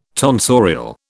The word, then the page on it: tonsorial: British English